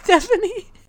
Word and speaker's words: Sthefani: It's Stephanie. S-T-H-E-F-A-N-I.